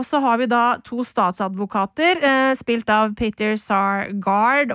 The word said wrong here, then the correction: Sarsgaard: og John Hugenacker. – Sarsgaard. – Sarsgaard. Sarsgaard. Altså vanskelig å uttale. – Peter Sarsgaard. – Sarsgaard, Med to a-er.